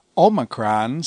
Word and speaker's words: omicron: Omicron sounds like the O in omelet. Others will pronounce it as the O in obey, as in modern Greek.